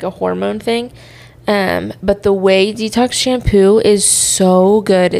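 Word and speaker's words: Ouai: And then I have the Way. That's how you say it right now.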